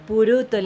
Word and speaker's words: Pluto: Puruto le dicen, como los japoneses no pueden pronunciar eh, dos consonantes juntas. Tienen que llevar toda.